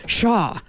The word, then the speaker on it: pshaw: P-S-H-A-W. Now, it's supposed to be pronounced shaw. Shaw, but we say pshaw.